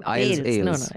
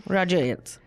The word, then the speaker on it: Ailes: How do you pronounce his name? – Ailes. – Because on Hafta, I think I mispronounced it. – Roger Ailes. – So, well, for those of you, Bombshell is a film about Roger Ailes, the guy who created Fox News.